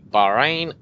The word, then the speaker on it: Bahrain: So then we move to Bahrain. Am I saying that right, Peck or Evan? – Yeah, you're saying it right.